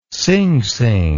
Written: singsing

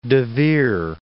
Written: De Vere

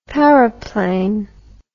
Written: paraplane

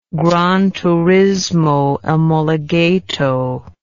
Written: granturismoomologato

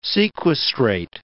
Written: sequestrate

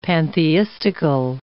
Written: pantheistical

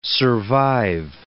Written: survive

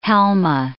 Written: halma

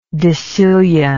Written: decillionth

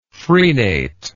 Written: frenate

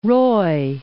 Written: Roy

